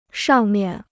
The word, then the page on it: 上面: shàng miàn